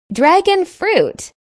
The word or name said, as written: dragon fruit